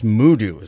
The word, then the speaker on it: Muhdo: He works with a DNA testing company called Moodoo. Am I getting that right, Chris? Moodoo is how you say it. Yeah, that's the one. Yeah, that's how you say it. – M-U-H-D-O, Moodoo.